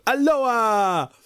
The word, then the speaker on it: Alloa: If you can get to Aloha, by the way, that's spelled A-L-L-O-A, Tower, on August the 28th, we have another calendar ghost.